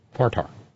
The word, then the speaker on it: Fartar: And it and the local pronunciation for fire tower is Fartar. Up on Fartar Mountain. Fartar. F-A-R-T-A-R, Fartar.